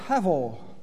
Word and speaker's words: hevel: Even the pronunciation of hevel, hevel, reflects this literal meaning of breath. As you pronounce the word, you can feel the breath coming out of your mouth.